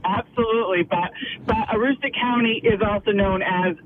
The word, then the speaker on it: Aroostook: Aroostook. – You have a great day. – Thank you. Thank you so much for your help. – Yes. Bye. – Bye-bye. Aroostook County. Aroostook.